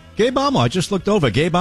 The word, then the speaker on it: Gabe Amo: Joining me now is Democratic congressman from Rhode Island, Gabe Amo.